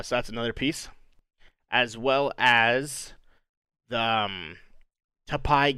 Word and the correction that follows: Taipei: Game Show. Uh, I believe that's how you say it. The Taipei Taipei Game Show um, has been canceled, uh, and that was supposed to take place in China. Taipei. Taipei. – Yeah, there we go.